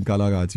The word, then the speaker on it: Nkalakatha: Yeah, you pronounce it correctly. It's actually Nkalakatha.